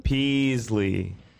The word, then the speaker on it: Pezley: Am I saying his name right? – Pezley. – Yep. Pezley.